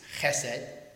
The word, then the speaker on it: chesed: It's chesed. That's how it's pronounced in a Hebrew format.